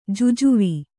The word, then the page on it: ಜುಜುವಿ: kannada